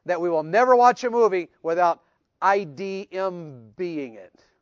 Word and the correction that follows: IMDb: Did I say that right? – IMDb.